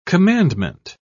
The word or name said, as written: commandment